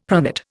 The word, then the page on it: pronate: Indian